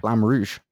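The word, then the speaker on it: Flamme Rouge: Flam Rouge. – Flam Rouge. – It's French. – Oh, okay. – It means the red.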